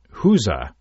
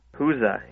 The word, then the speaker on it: gueuze: Now, number six is a blended lambic, and you pronounce it Huza. – Huza, yeah. – Because it looks nothing like Huza. – Yeah, no, it's spelled G-U-E-U-Z-E, but it's or one of the pronunciations of it is Huza.